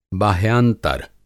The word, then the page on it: बाह्यांतर: Hindi